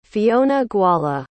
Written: Fionnaghuala

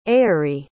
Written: aeary

